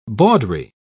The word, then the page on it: bawdry: British English